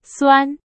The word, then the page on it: 酸: Chinese